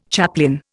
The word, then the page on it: chaplain: Indian